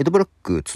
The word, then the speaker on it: EDBL: えー、エド・ブラック、綴りとしてはね、 EDBL と書くんですけども、 EDBL と書いてエド・ブラックですね。